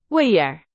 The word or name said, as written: 味儿